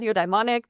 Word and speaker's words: eudaimonic: In your kind of research, you talk about hedonic well-being and eudaimonic.